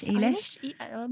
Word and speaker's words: Eilish: Eilish.